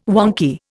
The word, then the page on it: wonky: Indian